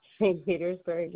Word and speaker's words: St. Petersburg: Is at the cemetery, South Pittsburgh, Florida. Well, I said it wrong, but yeah. – St. Petersburg, yes.